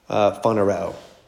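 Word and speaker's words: Phanereo (φανερόω): Second person plural from phanereo. Phanereo is how you say that.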